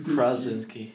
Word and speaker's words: Krasinski: John Krasinski.